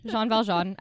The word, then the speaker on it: Jean Valjean: So he's trying to portray himself as Jean Valjean. Am I saying that right? – Yep. Yeah. – Yep. Jean Valjean as a rebellious protagonist.